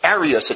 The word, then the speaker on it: Arius: St. Augustine noteth this for a special heresy, saying, Arians are named of one Arius, it's Arius, a different pronunciation,